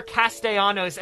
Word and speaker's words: Castellanos: You're looking for Castellanos, and that is correct.